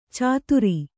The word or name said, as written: ಚಾತುರಿ